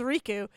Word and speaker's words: Riku: It's Riku.